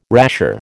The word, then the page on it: rasher: American English